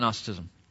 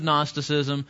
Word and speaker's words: Gnosticism: What else do you know about Gnosticism? Does that pretty much exhaust it? Starts with a G. Yeah, you don't pronounce the G.